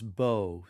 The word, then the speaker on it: bow: Be sure to pronounce bow so it rhymes with no, not now, to